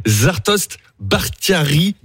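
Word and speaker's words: Zartoshte Bakhtiari: Merci d'être en avance, Zartost Bakhtiari, est-ce que je prononce bien votre nom? – Très bien.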